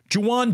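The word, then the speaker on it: Jauan: Juwan Jennings. It's a J-A-U-A-N, by the way. It's Juwan is spelled funny, but I've done it enough now to where I can at least get that done.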